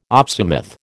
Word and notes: opsimath: American English

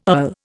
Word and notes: earl: Indian

